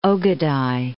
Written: Ogadai

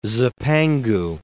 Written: Zipangu

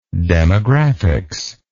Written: demographics

